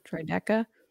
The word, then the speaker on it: Droideka: We have the Black Series Droideka. I always say that wrong. – I think Droideka. That's right. Yeah. – Droideka.